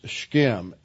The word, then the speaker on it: Shechem: That's how you pronounce the Hebrew for Shechem. It's Shechem.